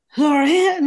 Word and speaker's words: Laurent: So how do you say it properly? – Laurent. – Laurent. – Yeah, there you go.